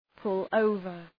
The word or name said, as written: pull over